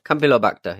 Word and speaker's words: Campylobacter: Campylobacter? How do you say that? – I've not got the story up, but I think it's Campylobacter. – Campylobacter, yeah.